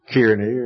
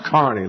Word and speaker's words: Carney: Several years ago, a young man named Steve Carney, and you'd pronounce it Kearney or Kearney or something like that, but it's pronounced Carney, like Kearney, Nebraska, went to South Africa.